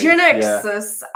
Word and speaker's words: Genexus: Genexus, I'd say. – Genexus.